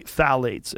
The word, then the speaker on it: phthalates: And when the introduction of phthalates, it starts with a P, P-T-H-A-L-A-T-E-S, I think it's how you spell it. – Mm-hmm. – You say phthalates, and phthalates are a type of chemical that disrupts the human body.